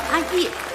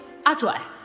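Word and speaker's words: 阿曳: 的名字叫阿叶，千万别读成阿拽啊，摇曳的曳。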